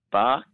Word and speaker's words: Bach: Bark, interesting former Kiwi, pronounced Bach, Johann Sebastian Bach, a German composer from the 1700s.